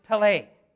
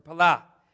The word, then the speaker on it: pele: The Hebrew word for wonderful is pele, or pala, depending how you pronounce it.